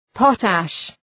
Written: potash

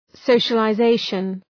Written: socialization

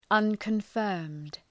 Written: unconfirmed

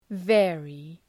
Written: vary

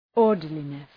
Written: orderliness

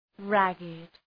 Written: ragged

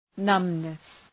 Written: numbness

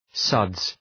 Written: suds

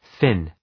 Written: Finn

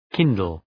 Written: kindle

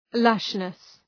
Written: lushness

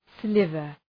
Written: sliver

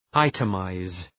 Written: itemize

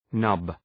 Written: nub

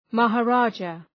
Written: maharaja

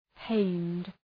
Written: pained

